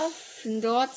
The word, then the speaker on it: Gryffindor: Gryffindor, zu eingebildet.